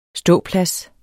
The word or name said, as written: ståplads